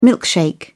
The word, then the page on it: milkshake: British English